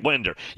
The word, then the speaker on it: Winder: I could only laugh when thinking of the show Breaking Winders and Jeff in the Borough. Yeah, Break at Winder. Yeah, that city is pronounced Winder, Georgia. But yes, that is kind of funny. Shannon. W-I-N-D-E-R.